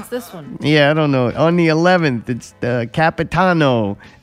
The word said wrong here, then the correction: Capitani: and it's Capitani. That was close.